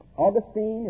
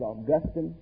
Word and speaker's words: Augustine: But Augustine is the way I pronounce his name. You may pronounce it Augustine.